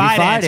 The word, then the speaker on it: Fidance: Fidance, like romance without Fidance.